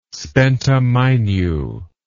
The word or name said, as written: spenta mainyu